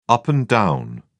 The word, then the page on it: up and down: British English